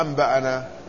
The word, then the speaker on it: أنبأنا: وهناك لفظة ثالثة وهي لفظة أنبأنا، هذه يكتفون بكتابتها نون ألف فتقرأها أنبأنا.